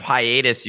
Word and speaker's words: hiatus: I've seen it, but I also liked how you, instead of hiatus, you called it hi-hatus. – Hiatus. – It's a dirty South pronunciation of hiatus.